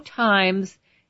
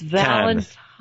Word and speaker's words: Valentine's: No, there's no times. It's not a newspaper. It's Valentine's. – Times. – Like the tines on a fork. – Okay. – So fork you if you cannot say it correctly.